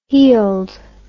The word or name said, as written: heald